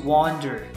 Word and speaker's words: wander: 那这里是 wander around，我这里是打错的。 Wander W-A-N-D-E-R 是对的，短信中我打成 W-O-N。 W-O-N 不仅读音不一样，这个意思也不一样，要注意一下哈。 W-A-N-D-E-R 读成 wander， W-O-N-D-E-R 这个读成 wonder，表示什么？